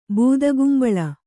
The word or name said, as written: ಬೂದಗುಂಬಳ